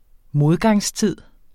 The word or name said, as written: modgangstid